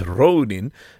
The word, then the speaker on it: ronin: So because Ronin is actually, if you were to say it in Japanese, it's Ronin, but in English, we don't really have that sound of like, oh, so we like a lifting up of the O, so it's kind of a long O sound, so it should be written R-O-U-N-I-N.